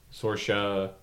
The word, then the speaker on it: Saoirse Ronan: Saoirse. – Ronan? – Saoirse Ronan.